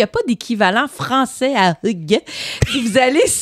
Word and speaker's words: hygge: Mais c'est parce que ce qu'il faut savoir, c'est que ça s'écrit H-Y-G-G-E, mais ça se prononce Hug.